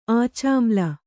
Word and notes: ಆಚಾಮ್ಲ: ācāmla

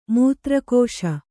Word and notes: ಮೂತ್ರಕೋಶ: mūtra kōśa

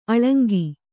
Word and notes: ಅಳಂಕು: aḷaŋku

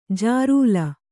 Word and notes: ಜಿರಲೆ: jirale

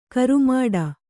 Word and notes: ಕರುಮಾಡ: karumāḍa

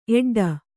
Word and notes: ಎಡ್ಡು: eḍḍu

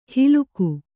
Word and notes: ಹಿಲುಕು: hiluku; Kannada

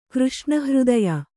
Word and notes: ಕೃಷ್ಣಹೃದಯ: kannada

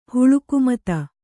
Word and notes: ಹುಳುಕುಮತ: Kannada

